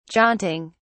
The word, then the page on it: jaunting: British English